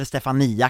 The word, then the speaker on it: Stefania: Den här låten skrevs ju som en hyllning till hans mamma Stefania, eller Stefania kanske hon, det uttalas eftersom han sjunger så.